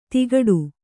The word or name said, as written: ತಿಗಟು